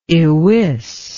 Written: ywis

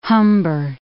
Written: Humber

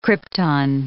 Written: krypton

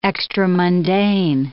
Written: extramundane

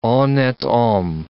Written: honnete homme

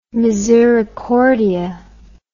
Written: misericordia

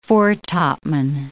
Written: foretopman

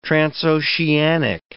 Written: transoceanic

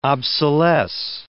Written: obsolesce

